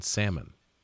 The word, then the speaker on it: salmon: You know the L is silent in that word. – What Chardonnay? – No, in salmon.